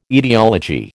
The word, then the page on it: etiology: American English